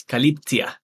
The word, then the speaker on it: Calyptia: We pronounce the company name as Calyptia.